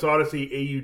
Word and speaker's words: Audacy: That's Odyssey, A-U-D-A-C-Y, and pronounced Odyssey, A-U-D-A-C-Y.